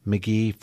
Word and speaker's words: Magee: You can go to my website, and for your viewing audiences, my last name is spelled M-A-G-E-E, and my Facebook page is Ken McGee for Sheriff, and it gets into more details on this, as well as an article that was published in the Courant.